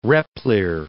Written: Repplier

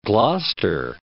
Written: Gloucester

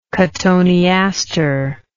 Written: cotoneaster

